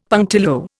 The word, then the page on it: punctilio: Indian